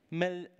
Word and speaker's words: Maleficent: And I went to see a film I have a struggle, Maleficent, that's how it's said.